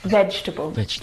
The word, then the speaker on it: vegetable: Now, this word is a challenge because it looks like there should be 4 syllables in this word. Vegetable. – Vegetable. – But there's not. There are 3 syllables. Veg, we don't pronounce that second syllable.